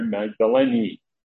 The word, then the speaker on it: Magdalene: Now you heard me pronounce it that way. Maybe you've heard people pronounce it Mary Magdalene. It's interesting, in the Greek, the last letter is neta, and it has an accent on it.